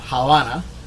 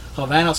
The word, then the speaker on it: Havana: The guy, he calls it Havana with a V, not with a B, not Havana. Havana, so tourists and Americans know how to pronounce it.